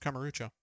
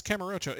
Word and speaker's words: Kamurocho: Oh, it's Streets of Kamurocho.